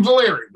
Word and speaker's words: Valeri: It's Jim Valeri, but that's okay.